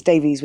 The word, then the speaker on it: Davies: And it's Davies with an IES.